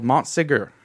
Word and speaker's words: Montségur: So if we go back even before that, in the area there is a famous castle called Montségur. – Montségur. – Montségur. – Montségur. – To use the correct French pronunciation.